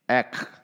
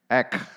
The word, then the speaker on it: ek: How do you pronounce the one in the middle? Ek. Ek. That's how it's in Nepalese.